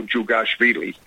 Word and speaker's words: Jugashvili: And Jew, it sounds like it starts D-H-U. Is, you know, and it's kind of pronounced, almost sounds like Jew, you know, Jugashvili.